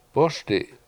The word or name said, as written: bOSdi7